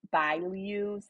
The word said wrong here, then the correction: Bilyeu: I'm probably not even saying it right. Um, it's Woman of Impact with Lisa Bilyeu.